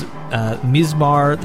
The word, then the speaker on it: mizmar: But there is mizmar, the nai flute or ney flute, and sanza.